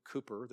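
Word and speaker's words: Cowper: I love this hymn from William Cooper, that is how you pronounce it, I think.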